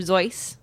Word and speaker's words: Seuss: And while it is originally Dr. Seuss, because that's how he would have pronounced it, once it sort of started getting bigger in America, he apparently took on the rhyming with goose, Zeus to rhyme with goose, because it was easier for kids.